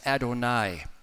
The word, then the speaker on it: Adonai: The Jews considered that name so sacred that they added vowels to make it be pronounced as Adonai, It's another word you've probably heard in the past, Adonai, also meaning Lord.